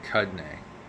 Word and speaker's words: Cudahy: Cudney? C-U-D-A-H-Y. Cudney. That's how I'm going to pronounce it.